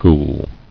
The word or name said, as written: Ghoul